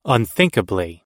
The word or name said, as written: unthinkably